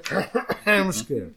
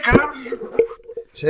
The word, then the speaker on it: Krampus: Hay que pronunciar bien, Luis. Tú que sabes de inglés, deberías saberlo. Es el Krampus. – Punto para Luis. es el Krampus.